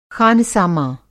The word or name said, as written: ख़ानसामां